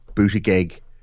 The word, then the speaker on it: Buttigieg: We look at the actions of, say, like Pete Buttigieg.